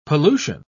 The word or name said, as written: pollution